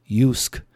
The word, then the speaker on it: JYSK: JYSK, pronounced Yusk, is well known for high-quality, on-trend products for the home, from the bedroom to the garden.